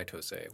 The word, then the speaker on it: TOSE: It was made by TOSE. We'll talk about them in a moment. – As you kids call it. But you actually pronounced it correctly.